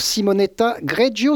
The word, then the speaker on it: Simonetta Greggio: Bonjour Simonetta Greggio, c'est comme ça qu'on prononce votre nom ? – Oui, c'est parfait, merci, bonjour.